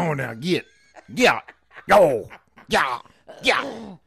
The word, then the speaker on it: gyatt: The next one is giat. GYATT. It's pronounced giat.